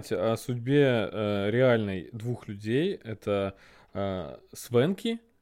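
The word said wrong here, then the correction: Swankie: я правильно сказал? Э, Свонки. Свонки, да.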